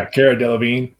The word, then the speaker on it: Cara Delevingne: She actually bet on Cara Delevingne. Is that how you say it? – Yeah, Cara Delevingne.